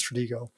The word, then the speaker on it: Stratego: Is it Stratego or Stratego? I think it's Stratego. – I think it's Stratego. I think it's a hard G.